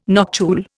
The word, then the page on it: noctule: Indian